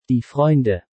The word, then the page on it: Die Freunde: German